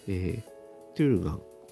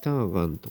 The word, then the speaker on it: Turgan: トゥルガン、 T-U-R-G-A-N ですね。ターガンとかって読むかなと思いきや、なんとなくフィンランド読みでトゥルガンと読んでしまう、私なのですが、そのトゥルガントリオという 3 人組のトリオによる、その名もトゥルガンという 2004 年のアルバムについてご紹介したいと思います。このトゥルガンというアルバムはですね、